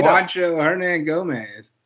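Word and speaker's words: Juancho Hernangómez: And Juancho Hermogunos, what was this? I can't say his name. – Juancho Hernan Gomez.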